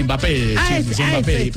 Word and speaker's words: Mbappé: Mbappé, pronúncelo así.